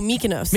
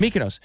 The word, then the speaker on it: Mykonos: Mykonos? – Oh, Mykonos. – Mykonos.